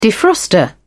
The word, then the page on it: defroster: British English